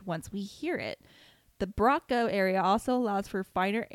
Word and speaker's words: Broca: There's no Z whatever, the Braca and Wernicke area. The Braca area allows language to be produced. the, the, mine's not working. The Broca